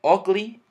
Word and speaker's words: ugly: Ugly, la U la vamos a pronunciar como ugly, significa feo.